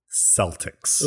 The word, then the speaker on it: Celtics: Celtics. – Ooh, there you go. Nailed it. – Did I say it right? – Not the Boston Celtics.